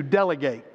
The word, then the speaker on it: delegate: This word has two pronunciations. When you first see the word, you probably think of the verb to appoint and send with a commission, to delegate. But as a noun, we pronounce it delegate, one who is sent for a purpose.